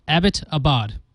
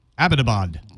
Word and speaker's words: Abbottabad: The city was named after a British major, Abbott. So its pronunciation is simple. Abbottabad. – Abbottabad.